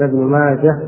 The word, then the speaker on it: ابن ماجه: الكاتب ضبط الهاء بالسكون، وهذا هو الصحيح، ذكر ابن خلفان أنها تنطق تكتب بالهاء وصلا وقفا، وروى ابن ماجه في سننه وصلا، وإذا وقفت كلها بالهاء ليس فيها التاء، هذا هو المعروف عن أهل العلم كما نص عليه ابن ماجه رحمه الله، والكاتب وضع عليها سكون بدون نقط، وهذا صحيح.